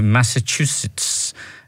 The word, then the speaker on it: Massachusetts: To Massachusetts. Is that how you pronounce it? There's too many syllables in there for me. – Yeah, that sounds right.